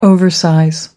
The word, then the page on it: oversize: American English